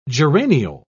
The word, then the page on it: geraniol: British English